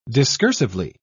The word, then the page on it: discursively: British English